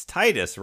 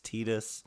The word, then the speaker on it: Tidus: Well, that's why the producer's there for when the voice actor goes, but it's Titus, right? And they're like, no, it's Tidus.